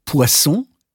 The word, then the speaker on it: poisson: Manchmal kann die Verwechslung stimmhafter und stimmloser Konsonanten bei der Aussprache auch fatale Folgen haben. Zum Beispiel die Verwechslung von poisson, Fisch, mit poison, Gift.